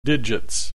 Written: digits

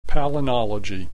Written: palynology

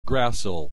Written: gracile

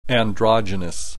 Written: androgynous

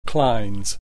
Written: clines

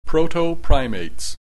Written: proto-primates

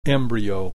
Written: embryo

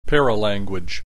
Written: paralanguage

